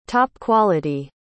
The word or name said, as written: top-quality